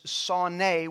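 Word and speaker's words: sonei (שונא): The word hate in Hebrew, spelled the way that we spell it, is S-A-N-E. It is sane. But in the pronunciation, the Hebrew pronunciation is sonei, which means literally to hate or despise or to be unloved.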